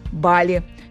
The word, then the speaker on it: Бали: Правильно, Бали. Филологи объясняют это особенностями языков, распространенных в Индонезии. Между тем, Юля выбрала авторское название для этого острова. Мне нравится ударение на «и», потому что это что-то такое экзотическое получается.